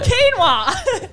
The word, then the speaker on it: quinoa: You're on the way to saying quinoa. – Quinoa. – How do you say that word? – Quinoa. – Wait, did I say that properly? – Yeah, you did.